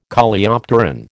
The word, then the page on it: Coleopteron: American English